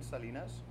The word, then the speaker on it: Salinas: Okay, it's Roen Salinas, R-O-E-N-S-A-L-I-N-A-S.